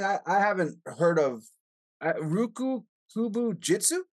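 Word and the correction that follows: Ryukyu Kobujutsu: Did I say that right? – When I was training under Anderson's Karate Dojo, he did a Kobudo system, which he called Ryukyu Kobu Jitsu, which I guess is just like Ryukyu Kenpo.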